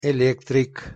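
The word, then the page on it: elektrik: Tatar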